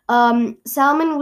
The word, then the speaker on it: salmon: And a couple of centuries ago, salmon was spelled S-A-U-M-O-N. So people pronounced it salmon. But then around that time, people were rallying for words to reflect Latin origins, right? And in Latin, fish is salmo without a silent L. And so people changed it to reflect the Latin origin. So now it's spelled S-A-L-M-O-N.